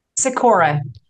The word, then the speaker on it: Skykoa: Skykoa.